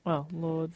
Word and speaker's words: Lourdes: I'm pretty sure the S is silent in Lourdes.